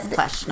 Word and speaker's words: Pletsch: Palmer and Pletsch. – Is, I'm assuming that's how you pronounce it. Which is P L E T S C H. But Plesh, not see, Palmer.